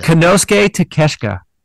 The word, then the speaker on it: Konosuke Takeshita: Have I just been mispronouncing his name? I really thought it was Takeshita. – No, you're saying Takeshita. – Konosuke Takeshita. – Takeshita. Okay, yes. Cool.